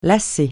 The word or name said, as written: lasser